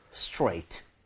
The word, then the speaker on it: straight: TR 모음이 이렇게 연결이 돼 있으면은 약간 치읓 비슷하게 가는 거. 이러면 뭐 크리스마스 트리 이거 아니죠? tree인 것처럼 해주시면 되겠습니다. 제가 발음 straight 두 번 드릴게요. 입모양 좀 모아서 혀 당겨줘야 자연스럽게 그 발음 납니다.